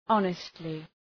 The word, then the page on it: honestly: ‘ɒnıstlı